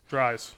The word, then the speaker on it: Dries: Dries, am I saying it right? Dries? – Dries? Yeah, Sheldon Dries. – Dries or Dries? – Dries. – Dries, yeah. I mean, two goals and the loss on Friday night.